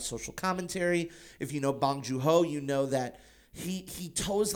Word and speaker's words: Bong Joon-ho: It's even though it's fairly easy, it's Bong Joon-ho.